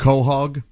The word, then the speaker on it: Quahog: In Franklin County, when he found the gigantic, how do you say that again? – Quahog.